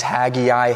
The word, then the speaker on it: Haggai: And Haggai, you could say it a couple of different ways. Haggai, Haggai. I say Haggai. I think that's the proper way to say it. Just basically say Haggai with a southern twang. Haggai. That's how you say it.